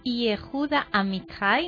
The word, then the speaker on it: Yehuda Amichai: que és el Yehuda Amichai, no sé si ho pronuncio bé.